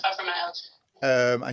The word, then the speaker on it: fibromyalgia: It's fibromyalgia, is it? – Oh yes, I have fibromyalgia.